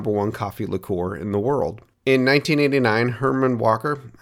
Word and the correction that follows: Hiram Walker: I've been mispronouncing this the whole time. It's actually Hiram Walker, so my apologies.